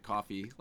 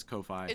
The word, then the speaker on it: Ko-fi: Some people would pronounce it coffee, like Cicero tried to tell me in last week's episode. I don't know who those people are. It clearly says Ko-Fi.